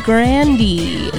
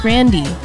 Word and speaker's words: Grande: Ariana Grande is how supposedly you're supposed to pronounce that. Supposedly it's Grande. That's how you're supposed to pronounce it. That's what she said. – Supposed to pronounce the E? – Grande.